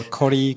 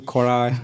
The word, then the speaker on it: Koray: Okay, everyone says it differently. Koray Tuğberk Gübür or Koray, Koray, Koray.